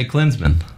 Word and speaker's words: Klinsmann: So, how do the Americans pronounce Jürgen Klinsmann? – Yeah, they just say Klinsmann.